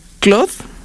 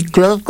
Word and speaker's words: Claude: Claude, que se escribe como Claude en español. Gemini o Gemini, que ese es el de Google. – ¿Claude? ¿Y Claude con qué más? – Claude es C-L-A-U-D-E.